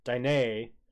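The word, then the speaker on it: Diné: The plentiful fresh water and prime hunting grounds were paradise to the Dine, pronounced Dine, as they called themselves.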